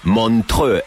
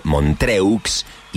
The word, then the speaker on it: Montreux: Montreux es el lugar, se escribe Montreux,